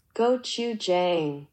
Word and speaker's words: gochujang: It's with a G. Gochujang. – Oh, it's not the C? – Gochujang.